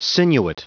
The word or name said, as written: sinuate